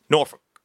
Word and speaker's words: Norfuk: yes, that is, the language is spelled N-O-R-F-U-K. It's Norfolk.